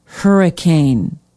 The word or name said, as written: hurricane